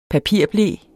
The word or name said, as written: papirble